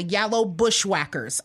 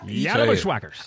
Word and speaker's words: Yellow Bushwhackers: You got to say it like they say it on the show. Yellow Bushwhackers. – Oh, they say the Yellow Bushwhackers.